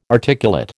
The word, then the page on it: articulate: American English